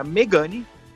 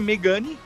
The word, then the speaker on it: Megane: Era Megane, com acento agudo no primeiro E, típico francês. E passou a ser Megane, sem acento, e por que não com uma sílaba tônica que você quiser. Então, como ele não tem mais acento, você pode chamar de Megane, Megane, Megané. Fica ao critério do comprador, né, Mira? – Isso aí, isso aí.